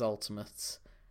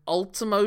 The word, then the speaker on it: Ultimo: We're going to talk about the ultimate belt collector, although they mispronounce it as ultimate. It's Starrcade 96. It's for the Cruiserweight Championship of Dean Malenko's against the 8 belt J crown of Ultimo Dragon.